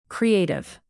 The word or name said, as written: creative